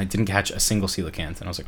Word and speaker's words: coelacanth: Is it called coelacanth or coelacanth? – Coelacanth.